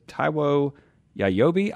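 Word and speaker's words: Taiwo Jaiyeoba: His name is Taiwo Yayobi. I hope I'm saying that correctly. – You got it.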